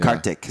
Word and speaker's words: Kartik: the month that I recommend people to go is the month of November, Kartika, right? Yeah. Kartik is how you say it? – Kartik, yeah.